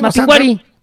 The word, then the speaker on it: Mapinguari: No, es que no tiene acento en la I. – No tiene acento en la I. – Mapinguari.